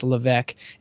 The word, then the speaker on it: Levesque: Yeah, it's spelled Levesque, but it's pr- pronounced Levesque, and the book is ask.